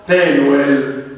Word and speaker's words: Penuel: Se ha un puntino al centro, si pronuncia con suono esplosivo P. Se manca del puntino, si chiama Dalish, si pronuncia in maniera più tenue F. Quindi Fanuele, se lo riporta il testo in greco, sarebbe in ebraico Penuel, che ritroviamo in Genesi 32-31.